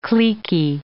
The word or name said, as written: cliquey